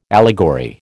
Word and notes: allegory: American English

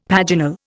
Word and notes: paginal: Indian

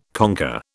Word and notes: conker: British English

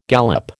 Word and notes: galop: American English

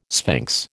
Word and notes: sphinx: American English

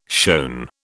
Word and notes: shown: British English

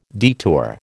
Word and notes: detour: American English

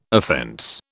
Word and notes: offence: British English

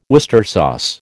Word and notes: Worcester sauce: American English

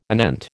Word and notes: anent: American English

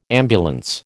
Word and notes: ambulance: American English

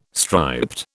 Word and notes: striped: British English

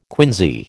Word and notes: quinsy: American English

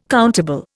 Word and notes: countable: Indian

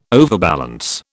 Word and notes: overbalance: British English

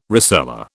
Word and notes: rosella: British English

